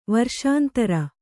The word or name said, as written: ವರ್ಷಾಂತರ